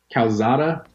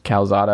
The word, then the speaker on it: Calzada: Calzada. – Calzada, I guess.